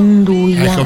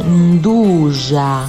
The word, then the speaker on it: nduja: chef, che è calabrese, mi ha detto che non si dice nduja, ma si dice nduja.